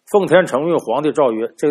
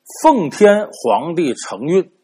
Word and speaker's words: 奉天承运: 圣旨奉天承运，皇帝诏曰，那就我得多说两句啊，您听着念圣旨，奉天承运，皇帝诏曰，这个念法不对，怎么应该怎么念呢？不是奉天承运，皇帝诏曰，是奉天皇帝承运，